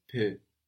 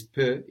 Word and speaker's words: peux: I can or I am able in French is je peux. – Je peux. – And this peux is spelled PEUX. We have that X, that silent X on the end there. What was a little? – Un peu.